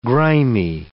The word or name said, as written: grimy